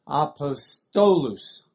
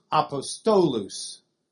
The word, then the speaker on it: apostolus: They're long, so you'd have the second syllable of the penultimate Apostolus. Apostolus. It's the way you would say it in Latin.